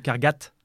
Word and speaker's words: Cargat: On va anticiper, mais Cargade, ça vient de Cargat, ouais. – Ça se prononce T et non pas D.